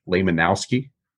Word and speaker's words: Lehmanowsky: It's John Jacob Lehmanowski. Is that correct? – I think I haven't heard it pronounced by anyone else, but that's how I would pronounce it too.